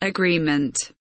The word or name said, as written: agreement